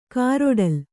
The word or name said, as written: ಕಾರೊಡಲು